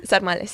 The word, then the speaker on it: sarmale: Bueno, pues vamos con 2 platos más, el sarmale, ¿se dice así? – Sí, sarmale, sí.